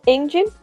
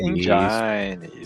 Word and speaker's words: Engine: Enquanto isso, a versão do PC Engine, né, foi desenvolvida pela não, não, não, não, não, não, não, não, errado, pronúncia. – Foi Engine? – PC Engine, isso aí.